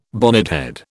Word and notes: bonnethead: British English

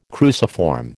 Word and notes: cruciform: American English

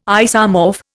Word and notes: isomorph: Indian

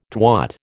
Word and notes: twat: American English

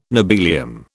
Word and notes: nobelium: British English

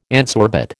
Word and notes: adsorbate: American English

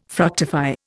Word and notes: fructify: Indian

